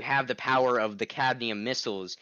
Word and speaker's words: cadmium: But then they tricked him into the, hey, let me try to pronounce it, the candium, how do you say that one? Does anybody remember? The cadmium? – I think it's cadmium.